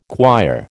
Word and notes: quire: American English

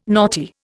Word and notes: naughty: Indian

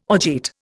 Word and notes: orgeat: Indian

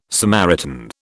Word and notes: Samaritan: British English

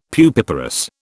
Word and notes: pupiparous: British English

